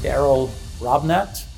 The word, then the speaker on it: Darrell Robinette: I'm here with Darrell Robinette.